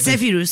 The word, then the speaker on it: Zephyrus: A ver, tú pronúncialo mejor porque a lo mejor... – Es el Zephyrus.